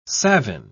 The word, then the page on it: savine: British English